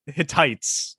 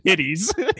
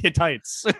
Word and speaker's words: Hittites: I think it's called Hittites. I don't think it's called Hitties. – Hitties, man. Big old Hitties. It's big Hitties. – Old, yeah. I think it's pronounced Hittites. – I think it's Hitties, man. – It's definitely not Hitties. – H-I-T-T-I-T-E-S is Hitties.